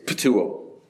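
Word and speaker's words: ptuo (πτύω): The Greek word here is ptuo. Can't make this up. We would spell it P-T-U-O, ptuo, to spit.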